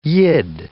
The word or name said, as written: Yid